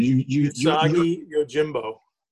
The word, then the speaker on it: Usagi Yojimbo: Usagi Yojimbo.